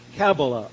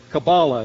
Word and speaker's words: Kabbalah: known as Kabbalah or Kabbalah. It's pronounced in different ways.